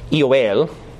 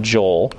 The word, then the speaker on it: Joel: The Greek term for Joel is Yoel. Right, which is basically like Yoel, or you know, and then we would pronounce it Joel.